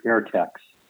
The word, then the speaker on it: AirTechs: Yeah, I have my best documentation about the Wright Field AirTechs, and it was AIR-TECS. Did I say that right? TECS, yes. AirTechs.